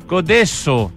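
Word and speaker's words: Codeço: ¿Dulce Codeso? ¿Codeco? ¿Cómo se dice? – Codeso. – Codeso. Tiene esa C con ese palito hacia abajo que es del portugués que nosotros no ocupamos en el español.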